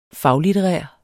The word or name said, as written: faglitterær